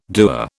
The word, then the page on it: dewar: British English